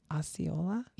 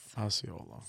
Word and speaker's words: Osceola: Osceola? – Yes. – Osceola.